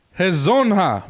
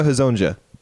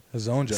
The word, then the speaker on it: Hezonja: Mario Hezonja. – It's close. It was Mario Hezonja. – Hezonja. So, the J was like... – It's either Hezonja or Hezonja.